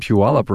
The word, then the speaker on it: Puyallup: Hey, a warm welcome to Rod Steffens from the Steffens Law Firm in Puyallup, Washington. Hey, Rod. – Hey, how are you doing today? – Hey, I'm great. I bet you're surprised that I said Puyallup right.